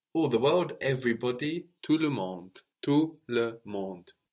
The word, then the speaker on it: tout le monde: Everybody in French, everybody, is tout le monde. Tout le monde. – Tout le monde. – So this is literally all the world. We have tout, T-O-U-T, silent T on the end, related to total, tout le monde.